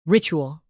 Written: ritual

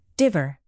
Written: dither

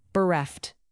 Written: bereft

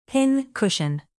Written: pin-cushion

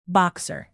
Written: boxer

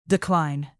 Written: decline

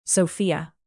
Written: sofia

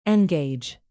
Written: engage

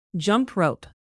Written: jump-rope

